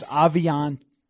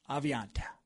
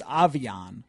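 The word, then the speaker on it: Aviante: It is Aviant. Aviant. – Aviant. There's no E. It's a silent E, although... This has been kind of a mess. Another thing from training camp. – Because his college pronunciation says Avion.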